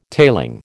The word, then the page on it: tailing: American English